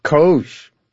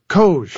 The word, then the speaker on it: COGE: How do you pronounce the acronym for this commission? – COGE. – COGE. – COGE. It is exactly what you think it is, Steve. And I know what's in the back of your mind when you ask that question. – Go on. What is in the back of my mind? – It does rhyme with Doge.